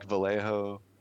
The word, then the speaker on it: Vallejo: I think Vallejo, I know I should pronounce the two L's as a Y sound because it's Spanish, but I can't seem to bring myself to do that.